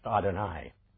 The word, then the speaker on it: Adonai: In the Hebrew text, that's often pronounced Adonai.